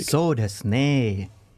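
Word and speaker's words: So desu ne (そうですね): Hansen, your pronunciation of was causing me physical pain. How would you pronounce what I was saying a lot last week? – So desu ne. – Okay. Apparently I was doing it incorrectly, which is very hard to believe.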